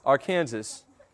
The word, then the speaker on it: Arkansas: But Arkansas.